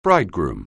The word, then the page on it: bridegroom: anglais